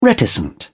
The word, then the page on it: reticent: British English